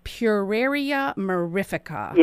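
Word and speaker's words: Pueraria mirifica: She is here today to talk about her new groundbreaking product, Amata, which is derived from the plant root, I hope I say this right, Puraria mirifica. – You got it right.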